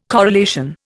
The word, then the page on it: corelation: Indian